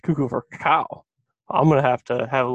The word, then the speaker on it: cacao: So, because it's spelled C-A-C-O or C-A-C-A-O. So I'm like, that's cacao. Not, you're right, that's actually cacao. It's not cocoa.